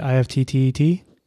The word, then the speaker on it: IFTTT: I don't know how to say it. IFTTT. You said right.